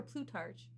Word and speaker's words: Plutarch: Is it Plutarch or Plutarch? – I think I was always saying, like, the H is silent. Plutarch? – Plutarch? – Yeah. Why put an H in there? – Anyway.